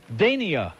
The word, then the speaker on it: Dania: Here's Dania, rhymes with Minkia.